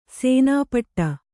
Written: ಸೇನಾಪಟ್ಟ

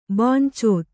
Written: ಬಾಂಚೋತ್

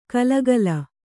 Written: ಖುಲಿಖುಲಿ

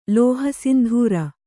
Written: ಲೋಹಸಿಂಧೂರ

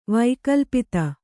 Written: ವೈಕಲ್ಪಿತ

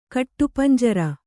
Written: ಕಟ್ಟುಪಂಜರ

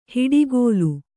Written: ಹಿಡಿಗೋಲು

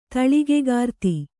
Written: ತಳಿಗೆಗಾರ್ತಿ

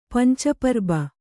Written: ಪಂಚಪರ್ಬ

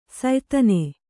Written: ಸಯದಾನ

